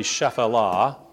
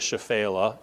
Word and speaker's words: Shephelah: In modern Hebrew, they call that the Shephelah, or the Shephelah. You'll hear it pronounced both ways, depending on old, new, whatever.